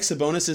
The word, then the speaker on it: Sabonis: I almost just said Sabonis. I've said his name so many times in my life, I almost just said it wrong. That was bizarre. I like Sabonis as a screener.